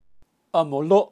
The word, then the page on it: amolo: Kaqchikel